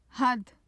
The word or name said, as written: hadd